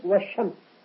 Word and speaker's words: الشمس: والشمس تكتب ولا تسمع في النطق، والشمس.